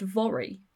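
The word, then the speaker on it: Voirrey: I think it's pronounced Vori, as in rhymes with lorry.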